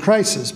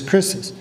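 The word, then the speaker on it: krisis (κρίσις): It is the word krisis. Krisis. And if you looked at it, you'd probably pronounce it crisis, but it's krisis.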